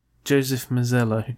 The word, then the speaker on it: Joseph Mazzello: Macniella. The guy who's playing Slade in Justice League. – Joseph Mazzello.